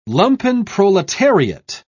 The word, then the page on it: lumpenproletariat: British English